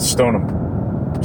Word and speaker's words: Stoneham: Uh, which, by the way, I was corrected on how to pronounce because I was phonetically saying it. It was Stoneham, and uh, and Jill, who pretty much grew up around there for a little bit of yeah. her life, she immediately corrected me. and said Stoneham. She's like, "There's no H. Say it right."